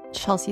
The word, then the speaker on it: Chelsey: Chelsey is spelled with EY.